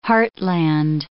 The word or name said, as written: heartland